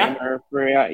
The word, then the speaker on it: Amber Ferreira: Amber Ferreira? – Amber Ferreira.